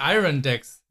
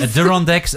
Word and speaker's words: Adirondack: Ist ja auch der Adirondack Chair. Adirondack. – Ich dachte, Adirondack ist richtig. – Okay, Adirondack. Wir haben es.